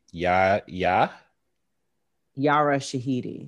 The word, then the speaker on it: Yara Shahidi: I was about to ask how you say her name. – Yara Shahidi. – Yara. – Yes. Mm-hmm. And it's basically. – I think I said Yaya. That's my bad.